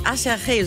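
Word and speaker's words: Aserejé: Las Ketchup schon von einer Combo namens Acherche, so wird die ausgesprochen, aber sieht aus wie Acherje, so kann man sich das auf jeden Fall gut merken.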